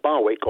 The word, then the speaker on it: Barwick: How do you pronounce it? Barwick. Or Barwick, that's right, yeah.